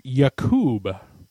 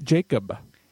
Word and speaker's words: Jakub: And we learned that from Tommy Mason that his first name, which is J-A-K-U-B, is actually pronounced Yakub. It's not Jacob. – Vileteri Vileipula is what I got to say to that. – Yeah, so it is not Jacob. It is Jakub.